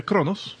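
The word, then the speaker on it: Qo'noS: Bueno, los Klingons sale que viven viven en un planeta que se escribe todo raro, pero se pronuncia Kronos.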